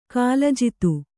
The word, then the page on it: ಕಾಲಜಿತು: kālajitu